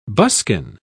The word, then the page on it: buskin: British English